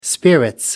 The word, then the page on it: spirits: British English